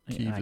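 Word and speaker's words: Kyiv: Kiev is the Ukrainian pronunciation. Kiev is the Russian pronunciation. So you can't say it that way anymore.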